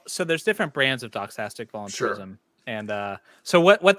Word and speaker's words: doxastic: And he kind of touches on this a little bit, you know, with the doxastic. It's spelled with an X, but it's pronounced with an S.